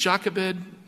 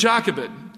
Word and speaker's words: Jochebed: Did I say that right? I never get it. I think it's Jochebed. That's how my English translation says it.